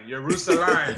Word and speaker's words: Jerusalem: Yeah, so I was going to ask just about the basketball aspect, going from the NBA to, let me make sure I say it right, Jerusalem, that's how you say it, right? – Jerusalem, there you go.